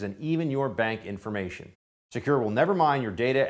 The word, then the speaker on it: Sekur: It's called Secure. It's spelled S-E-K-U-R.